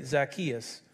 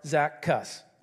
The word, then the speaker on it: Zacchaeus: Maybe that wasn't your story, but maybe you've heard about the story of Zacchaeus, or if you say it correctly, Zacchaeus, or Zaccus.